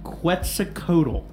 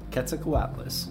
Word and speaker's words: Quetzalcoatlus: If not for his surprise, I'm probably going to pronounce it wrong, Quetzalcoatl. – Quetzalcoatlus.